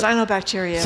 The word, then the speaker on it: cyanobacteria: Cyanobacteria.